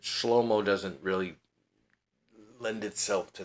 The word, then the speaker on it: Shlomo: And Solomon's name, I've said this before, but I'll tell you, the Hebrew pronunciation of these particular Hebrew letters is not Solomon. So if you say it in English, you may have heard this word in Hebrew cultures and circles, Shlomo. That would be his name, Shlomo.